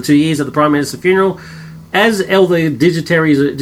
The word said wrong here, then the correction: dignitaries: digitaries, so you pronounce it? Dignitaries. Dignitaries.